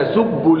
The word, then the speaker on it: تسبوا: وقوله تعالى ولا تسبوا الذين ولا تسبوا الذين كأن نطقت بباء مضمومة فقط مع أن بعدها واو مرسومة تسبوا لكن لا تنطق هكذا واو إلا إذا وقفت عليها فتنطق ولا تسبوا في الوصل ولا تسبوا الذين حذفت وصلا وثبتت وقفا.